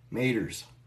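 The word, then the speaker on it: Maitre: Apparently, it is these M-A-I-T-R-E. I looked them up. They call them Maiters. Maiters. That's exactly how you say it. Pretty much how it's spelled.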